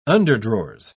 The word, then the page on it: underdrawers: British English